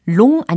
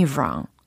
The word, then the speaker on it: wrong: 롱 아니고 롱 아니고 wrong 이렇게 발음을 해 주셔야 되겠습니다. 그리고요, 이 문장을 좀 짧게 줄여서도 전달할 수 있어요. 예를 들어서 don't take me wrong, don't take me wrong 이런 표현도 있습니다.